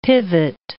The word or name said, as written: pivot